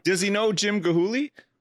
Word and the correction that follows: Chihuly: Dale Chihuly, sorry.